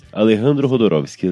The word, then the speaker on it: Alejandro Jodorowsky: Eu quis falar certinho o nome na pronúncia do espanhol. Aí eu não sei se eu falei certo. Deixa eu só conferir aqui. Alejandro Jodorowsky, exatamente.